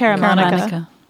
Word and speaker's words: Caramanica: I don't know what John Caramanica, is that how you pronounce it? I think it's Caramanica.